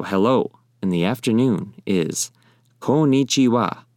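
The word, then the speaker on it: Konnichiwa (こんにちは): Konnichiwa. Once again, konnichiwa. That is how you say hello in the afternoon in Japanese.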